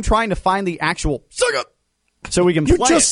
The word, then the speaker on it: Sega: No, it's Sega. Sega. Seg. Egg. Egg. Like egg. Seg.